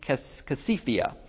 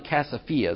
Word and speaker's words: Casiphia: It's curious that the text makes a point of referring to probably Casiphia is probably the best way to pronounce it, as a place.